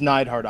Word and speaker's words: Neidhart: And it is Neidhart. I apologize for mispronouncing his name.